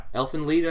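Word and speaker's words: Elfen Lied: I don't know how to pronounce it, Elfin Lied or Elfin Lied? – Oh, yeah. – Elfin Lied, I think is how you pronounce it. – I think that's how it's pronounced, yeah.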